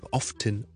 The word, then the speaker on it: often: 嘅 ok 咁但系读呢亦都得所以 often often often 同埋 often often often 都得咁大家自己留意一下啦通常招叔个感觉呢就系呢我到 often 呢多一个音呢系听落去感觉呢系比较正式少少 often often often often 呢听落去呢系好似懒少少少少 often often often，但系两个都系完全正确嘅发音，系唔需要担心嘅。